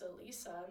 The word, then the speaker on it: Elisa: And, like, it's pronounced Elisa, not Eliza.